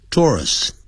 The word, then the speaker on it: tourists: 这位歌手很受到我们的欢迎。这边还是很受到观光客的欢迎。Tourists, 啊这个念成 tourists, 哦 tour, tourists.